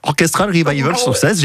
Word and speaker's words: Orchestral Revival Success: Attention, mon accent anglais. Orchestra Revival Success. – Ah bah avec ça forcément. Oui, d'accord. Orchestral Revival Success, j'ai bien compris. – Voilà, c'est comme ça se prononce.